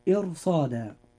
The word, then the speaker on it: إرصادا: الراء في هذه الكلمة راء مفخمة لأنها جاءت ساكنة وما قبلها مكسور وبعدها حرف استعلاء، ولذلك ننطقها هكذا: إرصادا.